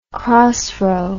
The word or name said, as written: crossrow